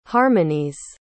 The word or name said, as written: harmonies